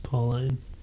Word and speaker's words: Pauline: It's spelled like Pauline. – Yeah, it's, I think it's mostly pronounced Pauline.